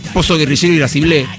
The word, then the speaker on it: Pozo Guerrillero Irascible: Es más, para nosotros en realidad es Pozo Guerrillero Irascible, con acento en el final. Como lo dice Palo en Tazas de Té Chino.